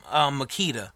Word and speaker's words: Makita: So I have some Milwaukee, I have some DeWalt, I have some Makita, if I'm pronouncing that right.